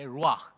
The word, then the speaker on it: ruach: Okay, ruach. If you want to say it properly, you got to have a little bit of phlegm in your throat. Ruach. If not, it's going to sound rough.